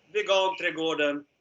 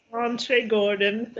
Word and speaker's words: Vegan Trädgården: You're here in southern Sweden of Vegan Trädgård. Am I saying it correctly? – Yeah, Vegan Trädgården. Yes. – Vegan Trädgården.